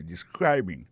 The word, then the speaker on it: describing: Ça nous carré là, ça nous constater là, nous ca constater que il y a prononcé mot de describing. Accent tombé sous deuxième syllabe là.